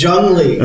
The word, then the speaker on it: Junglee: We were the second I always pronounce it Jung Lee. – Yeah, Jung Lee. – Their you just said client. Jung Lee. – It's Jung Lee. – Okay. – J-U-N-G-L-E-E.